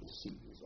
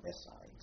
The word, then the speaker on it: Messines: Messini's was a big battle in France in the First World War, a place called Messini's or Messines. So I call it Messini's, because that's the way they pronounce it in Catholic.